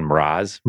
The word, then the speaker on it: Mraz: M-R-A-Z. Jason Mraz. – Mraz. Yeah.